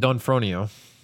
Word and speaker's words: D'Onofrio: Vincent D'Onofrio. – D'Onofrio. Cast as Oz in NBC series Emerald City. – Did you watch Daredevil on Netflix yet? That's a no. That's a no. When you do, Vincent D'Onofrio plays Kingpin, but you know his face.